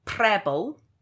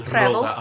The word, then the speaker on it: Prebble: This play is by Lucy Prebble. That is how you say it, isn't it? It's like pebble with an R. – I'd say Preble, but you'd say Preble. – Okay, Lucy Prebble.